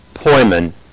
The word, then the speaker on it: poimen: The word that we are going to be seeing in 1 Timothy chapter 1 is also a word that is pronounced poimen.